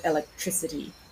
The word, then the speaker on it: electricity: So for instance, we have electric with the stress on the second syllable, but electricity with the stress towards the end.